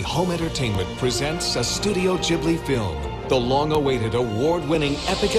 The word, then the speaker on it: Ghibli: Ghibli. I don't even know how to say that one. – It is Ghibli.